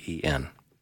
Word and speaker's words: Mulliken: Mulliken.